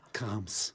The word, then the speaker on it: Charms: Charms. Charms, ja. Charms muss aber ausgesprochen Ja, CH. Krams. Kams.